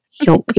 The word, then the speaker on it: yolk: Yolk. Yeah. – Yolk. Yes. – It rhymes with joke. – Yes. The L is pretty silent in there.